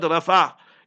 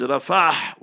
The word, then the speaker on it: Rafah: You Muslims learn how to pronounce. Not Rafah. It's Rafah with a ha in the end.